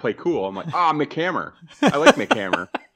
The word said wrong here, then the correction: MC Hammer: He's like, did you call him Mick Hammer? So I'm like, what's his, how do you say it? He's like, MC Hammer, not Mick Hammer.